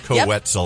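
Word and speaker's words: Koe Wetzel: Yes, rhymes with pretzel. Co-Wetzel.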